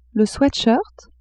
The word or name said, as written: sweat-shirt